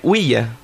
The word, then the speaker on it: Ouija: En realidad, además, como se debería de pronunciar, es Ouija, porque significa sí en francés, oui, y ya en alemán, que también es sí, o sea, sí, sí, ¿vale?